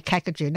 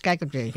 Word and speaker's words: Kakadu: And the funny thing is, we didn't even have a K. So everywhere you saw a K, it was always pronounced as a G. It's just like, they say Kakadu. No, it's not. It's Gagadu, So you just got to learn from the elders.